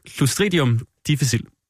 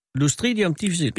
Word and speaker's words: Clostridium difficile: Clostridium difficile. Clostridium difficile.